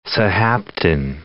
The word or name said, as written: Sahaptin